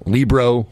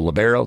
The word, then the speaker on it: libero: So libero or libero, I've always been told libero. That's what I've said for years. That's what I go with. There's acceptable two ways of pronouncing it.